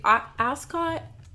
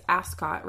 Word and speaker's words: Ascot: So the Ascot race course, the coolest thing I think that I learned was the fact that we keep saying it's Ascot, right? But they pronounce it Ascot.